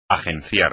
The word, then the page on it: agenciar: espagnol